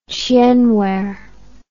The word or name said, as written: chien ware